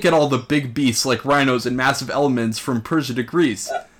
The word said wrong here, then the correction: elephant: I'm sorry, can you give pronounce elephant one more time for me? Because you botched it elephants.